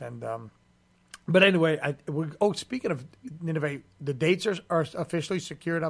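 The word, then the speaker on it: Ninove: That's how you say it, right? Nineveh? – That's right, yeah.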